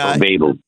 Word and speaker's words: Babel: I think in the book they call it a babblefish, but Babel.